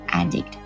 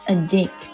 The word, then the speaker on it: addict: Addict，我一看这个单词啊，脑海当中出现的就是瘾君子毒瘾发作的场面的、再提醒大家一次啊，这个 addict 它作为名词呢是读作 addict，重音在第一个音节上，表示什么什么上瘾的人。但它作为动词的时候是读作 addict，表示对什么上瘾呢。